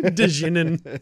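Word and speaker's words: Djinn: Dijin, you pronounced all of the letters. – I did. Dijin. – You noob. Dijin.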